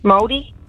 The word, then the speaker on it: Maori: Maori? – Oh. And you pronounced it beautifully. It's English.